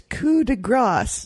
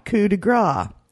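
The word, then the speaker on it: coup de grâce: Peter in Atlanta wrote and said in his subject heading, "It's coup de grace, not coup de gras." You pronounce it. – Damn, he's right. – You pronounced it coup de gras, and that is wrong. It should be coup de grace. The key here is that gras, fat, is gras, and grass, grace, mercy, is grass.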